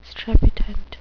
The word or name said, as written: strepitant